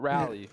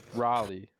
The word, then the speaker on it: Raleigh: Yeah, they want you to say Raleigh. Not Rally. Raleigh. – Raleigh? – There you go.